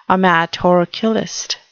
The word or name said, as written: amatorculist